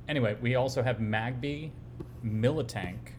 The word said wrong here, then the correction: Miltank: I think it's just Miltank. There's no second I, is there? – Oh, man, I thought there was. – Yeah, it's just Miltank. Well, there we go. I've been... Very much like other mispronunciations that have just become like mental canon for us. Like, I've been thinking it was Militank, like military, because it's a tank.